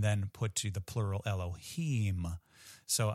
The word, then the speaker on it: Elohim: And it is not actually Elohim with an M sound at the end, but Elohim,